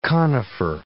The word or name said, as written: conifer